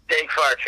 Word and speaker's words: Daeg Faerch: You know, I don't really know how to pronounce it. Uh, Daeg Daeg Faerch. – Daeg Faerch, yeah. – Brilliant.